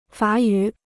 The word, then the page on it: 法语: fǎ yǔ